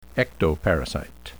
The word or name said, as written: ectoparasite